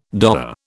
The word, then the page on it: doer: British English